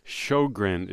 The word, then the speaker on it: Sjogren: S-J-O-G-R-E-N. Shogrin is how they pronounce it.